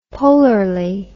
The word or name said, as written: polarly